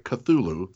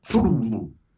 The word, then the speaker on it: Cthulhu: Here's the proper way, by the way, to pronounce Cthulhu. We all say it Cthulhu, three syllables.